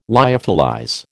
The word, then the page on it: lyophilize: American English